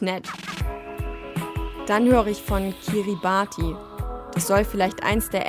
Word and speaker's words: Kiribati: Ausgesprochen Kiribati.